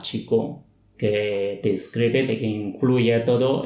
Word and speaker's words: Qigong: Normalmente cuando escribimos como Q-I-Chi y G-O-N-G, Kung es la pinyin. Pinyin, uh-huh, que es la manera de pronunciar las letras cuadrados chinos. Hay una manera de decir cómo se pronuncia. Entonces es Qigong.